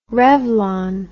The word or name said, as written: revlon